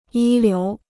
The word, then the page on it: 一流: yī liú